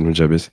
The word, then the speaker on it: Nujabes: Enfin, Nujabes. En vrai, y a pas grand monde qui sait comment ça se prononce non plus. Mais on est plutôt d'accord pour dire que c'est Nujabes.